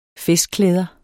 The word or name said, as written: festklæder